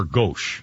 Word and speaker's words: gauche: Or gauche, that's how I pronounce it.